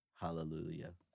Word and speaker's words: Hallelujah: You do a very soft H when you say Hallelujah. – Hallelujah, yeah, probably. Yeah. Yeah, I don't think I pronounce the H when I say Hallelujah.